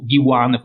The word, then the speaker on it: Diwan: Uh, di André Divan, che o oh, Diwan forse è meglio perché Divan sembra troppo divano e, e non, non, non, ci piace questa, questa italianizzazione assoluta.